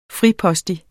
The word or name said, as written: fripostig